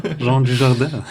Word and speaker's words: Jean Dujardin: Ja, Jean Dujardin.